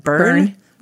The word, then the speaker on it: Byrne: How would you pronounce that? – Byrne. – B-Y-R-N-E.